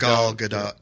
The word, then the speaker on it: Gal Gadot: But, yeah, I mean I – and, dude, that – how do you say her name? Is it Gail or Gal Gadot? – Gal Gadot.